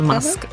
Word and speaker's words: Musk: Bu adamın adı nasıl okunuyor? Elon Musk. Evet, evet doğru, pardon.